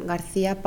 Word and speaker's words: García: Me llamo María García Palomo. – ¿Cómo se escribe su nombre? – M-A-R y con acento A. G-A-R-C y con acento A. P-A-L-O-M-O.